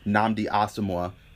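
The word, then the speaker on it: Nnamdi Asomugha: Nnamdi Asamoah, and I did say that correctly.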